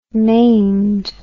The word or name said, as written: maimed